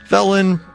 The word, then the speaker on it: Velen: Shout out to all the overkill Rogues on Velen, pronounced V-I-L-N, for being ungodly epic with 4K DPS in raids.